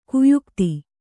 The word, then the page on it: ಗಾಯಕತೆ: gāyakate; kannada